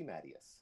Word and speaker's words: Matyas: My name is Rusty Mattias.